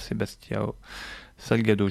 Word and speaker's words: Sebastião Salgado: Voilà, une création de danse contemporaine engagée et librement inspirée des œuvres du photographe Sebastião Salgado.